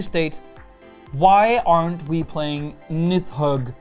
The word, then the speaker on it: Nidhogg: Well, it would seem that the leader's pretty much set in stone already, because today we are playing Nidhogg. – Yeah, or Nidhogg, as it would be pronounced properly in Icelandic.